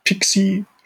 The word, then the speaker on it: PKCE: Ähm, das ist ähm, Pixie spricht sich diese Spezifikation aus, PKCE, Proof Key.